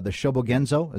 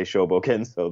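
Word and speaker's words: Shōbōgenzō: is from his work called the Shobogenzo.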